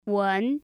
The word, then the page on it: wen: 2